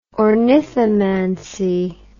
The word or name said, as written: ornithomancy